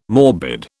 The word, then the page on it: morbid: British English